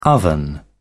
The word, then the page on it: oven: British English